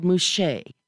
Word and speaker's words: Muxe: And they're called Muxe, and that is spelled M-U-X-E-S.